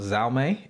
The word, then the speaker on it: Xiaomi: So, the company is pronounced Xiaomi. It's Xiaomi.